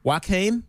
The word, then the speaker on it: Joaquin: Harlequin Phoenix played. Joaquin. Sorry, I say that wrong every time.